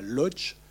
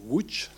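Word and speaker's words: Łódź: C'est l'école à Lodz, L-O-D-Z qui se prononce Wuch d'ailleurs,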